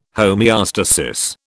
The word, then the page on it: homeostasis: British English